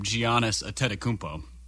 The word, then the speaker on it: Giannis Antetokounmpo: the name Giannis Antetokounmpo. – Is that really how you pronounce it? – It is.